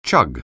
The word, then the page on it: chug: anglais